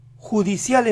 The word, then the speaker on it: judicial: Judicial empieza así, yu, yu. Díganlo con una Y marcada, yu. Nunca lo digan como si empezaran con I, yu. Tiene que ser yu. La siguiente sílaba es de, de. El inicio entonces es yude, yude, yude. La palabra completa, judicial, judicial, judicial.